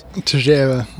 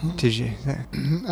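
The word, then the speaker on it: Teixeira: Jerry Texeria, or whatever his name is. – Tejera. – Tejera.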